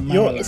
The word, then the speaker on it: Maryland: I believe it's pronounced Maryland.